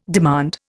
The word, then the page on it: demand: Indian